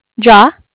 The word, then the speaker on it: Jia: Sure, it's JIA. JIA. It's a pretty short name. – How do you pronounce it? – Jaw. – Jaw. – Yeah. So pronunciation, I would say it was probably JAH. – Okay, but it's spelled Yeah. – J-I-A.